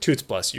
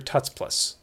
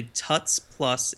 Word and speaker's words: Tuts Plus: And I pronounce it Toots Plus. You're Tuts Plus. – As a Tuts Plus instructor, it is called Tuts Plus. – But it's tutorial. – Tuts Plus. T-U-T-S.